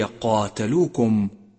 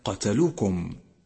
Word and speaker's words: قاتلوكم: ثالثا، إياك أن تغفل عن نطق الألف الصغيرة من كلمة قاتلوكم التي رسمت برسم خاص بالمصحف، فإذا أهملت نطقها اختلف معنى الكلمة تماما، فهي قاتلوكم وليس قتلوكم. رابعا، انتبه للحروف التي لونت في مصحف التجويد بلون رمادي، فإنها حروف لا تلفظ.